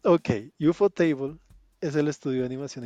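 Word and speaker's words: ufotable: Encima no lo podía pronunciar, mm. decía un fontable. Entonces, UFO Table eh,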